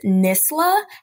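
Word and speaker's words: NSSLHA: So the correct pronunciation is NISLA. However, due to the different dialects around the country, specifically more so in the Midwest, a lot of people pronounce it NISLA with the H in the beginning, but it is NISLA with the H at the end.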